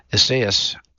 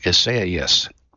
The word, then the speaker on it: Isaias: Eastern Time kickoff for the game, although the severity of the storm, named Tropical Storm Isaias, I'm saying that wrong, Isaias, it's a tropical storm, could change that in coming days.